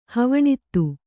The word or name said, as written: ಹವಣಿತ್ತು